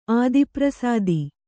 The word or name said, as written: ಆದಿಪ್ರಸಾದಿ